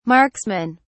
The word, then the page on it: marksmen: British English